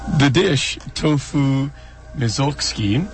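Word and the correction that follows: misozuke: Misozuki is a miso-cured tofu, hence the pronunciation misozuki.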